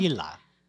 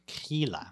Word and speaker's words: Grýla: Grýla. Pronounced like a K. Grýla. I just looked it up.